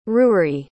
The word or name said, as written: ruri